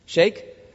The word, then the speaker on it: sheikh: I remember reading of an Arab, is it a sheikh or a sheikh? What's the proper pronunciation? Sheikh? Sounds like shake and fries or shake and bake.